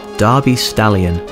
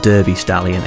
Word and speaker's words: Derby Stallion: Darby Stallion, or Derby Stallion if that's how you want to pronounce it.